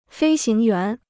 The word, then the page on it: 飞行员: fēi xíng yuán